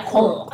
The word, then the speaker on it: Quirrell: Did I say his name Quirrell? – Yeah, Quirrell. Professor Quirrell.